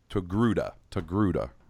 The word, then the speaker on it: Togruta: Togruta, right? T-R-O-G or T-O-G-R-U-T-A or something like that. – Yeah, sounds, you're saying it right to me.